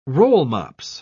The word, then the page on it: rollmops: British English